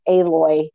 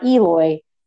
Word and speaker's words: Aloy: They say her name 475,000 times, and it's Aloy. Not Eloy every single freaking time.